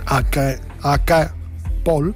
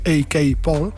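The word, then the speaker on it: A. K. Paul: AK Paul, AK Paul, y este Out of Time, Kelela.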